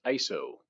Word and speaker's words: ISO: Actually, it's pronounced ISO, as in isosceles triangle, equal.